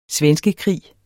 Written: svenskekrig

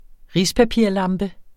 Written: RISPAPIRSLAMPE

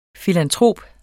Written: filantrop

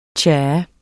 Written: tja

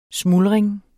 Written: smuldring

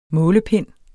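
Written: målepind

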